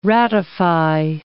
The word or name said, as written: ratify